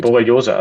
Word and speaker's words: Borreliose: Borreliose. Also das ist ein Borreliose, genau.